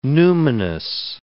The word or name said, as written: numinous